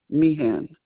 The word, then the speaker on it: Meighan: It's Michelle, M-I-C-H-E-L-L-E. The last name is spelled M-E-I-G-H-A-N, Meehan.